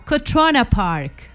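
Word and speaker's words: Crotona Park: Cotrona Park. La pronunciación, no sé si sea esta. Véngase por acá. – Cotrona Park, ok. – Eso, así debe ser, correcto.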